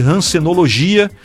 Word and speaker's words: Hansenologia: Lembrando que Hansenologia se escreve com H. A gente pronuncia com R por conta aí do bacilo de Hansen, que é a bactéria que causa a hanseníase.